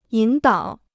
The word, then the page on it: 引导: yǐn dǎo